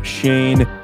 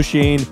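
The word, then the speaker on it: Schoen: They also moved on from their head coach, Joe Judge, and they are now hiring their next general manager, that being Joe Shane. That is the correct pronunciation, as we figured that out. We also have determined that is the exact pronunciation, but Joe Shane, the assistant general manager with the Buffalo Bills, will be the New York Giants' next head coach.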